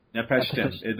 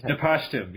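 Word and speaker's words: Napishtim: Usark of Nepotism, or however you pronounce it. – Nepashtim. There's no other I. I don't know where you're adding the second I to, but yeah. It's Nepashtim.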